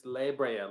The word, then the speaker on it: La Brea: it's La Brea, like library.